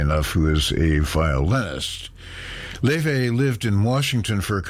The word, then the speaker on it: Laufey: She goes simply by her first name, which is spelled L-A-U-F-E-Y. Looks like Luffy, but is pronounced Leve. Leve is a half-Icelandic, half-Chinese artist.